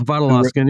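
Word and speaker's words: Vodolazkin: So you quote, and I'm going to try to pronounce this, Eugene Vodolaskin. – Mm-hmm. Vodolaskin, yeah, it's close enough.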